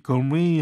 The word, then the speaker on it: gourmet: 여기 gourmet 할 때도 T가 발음이 안 돼요.